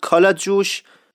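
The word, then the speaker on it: کله‌جوش: در مورد قضاوای صحبت شده بود، کالا جوش که در واقع همون کل جوش هست، تو اصفهان کالا جوش تلفظ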